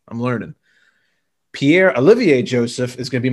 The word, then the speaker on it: Olivier: Pierre Olivier, not Oliver Joseph.